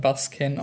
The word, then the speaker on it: Baskin: Pod, Hellions, Tales of Halloween, Cooties, Basken, ist ein türkischer Film, schreibt man Baskin, aber spricht man Basken aus, wurde uns gesagt im Kino und ich zitiere da jetzt den Veranstalter, Turbo Kid und Deathgasm.